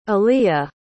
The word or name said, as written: aleigha